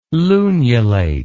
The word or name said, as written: lunulate